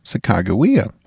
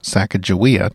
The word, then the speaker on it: Sacagawea: Sacagawea, or Sacagawea, depending on how you were taught to pronounce her name.